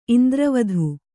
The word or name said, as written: ಇಂದ್ರವಧು